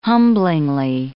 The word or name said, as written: humblingly